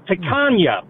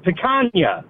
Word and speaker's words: picanha: You're totally pronouncing it wrong there, Nick. It's picanha. Picanha. Say it with me. Picanha.